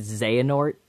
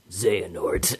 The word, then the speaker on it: Xehanort: is named Xehanort. – Which is just no heart with an X in it.